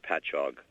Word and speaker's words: Patchogue: By the way, just for FYI, it's pronounced Patchogue. – Patchogue. That's such a weird name. I was having a hard time getting the syllables together. – Its counter on the other side is Catchogue.